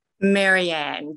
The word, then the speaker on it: Marianne: Marianne, Gilligan's Island.